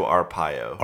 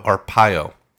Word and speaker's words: Arpaio: So, how do you pronounce his last name? – Sheriff Joe Arpaio. – Arpaio.